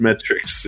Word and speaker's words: metrics: Matrix or metrics, if you're, but I know you're a big Keanu Reeves fan, so you can pronounce it however you want. – Advanced metrics, excuse me.